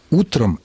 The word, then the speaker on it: утро: Само же существительное утро сохраняет ударение на корне во всех формах, в единственном и, и во множественном числе, если оно употребляется без предлога. Соответственно, утро, утро, утру, утро, утром, об утре.